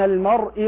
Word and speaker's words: المرء: المرء وزوجه، الراء مفخمة، بعض الناس ينطق المرء بترقيق الراء، وما هم بضارين، الراء هنا مرققة، بضارين بعضهم يفخمها بضارين هكذا وهذا خطأ، الراء مرققة.